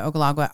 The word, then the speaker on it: Ogallala: Ogallala Aquifer.